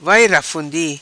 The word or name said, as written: Vai Rafundi